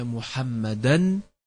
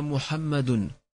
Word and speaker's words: Muhammadan: Ashhadu anna Muhammadan. T'entends là qui dit Ashhadu anna Muhammadun.